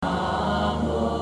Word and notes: 南无: nánmó

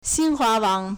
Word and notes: 新华网: xīnhuáwǎng

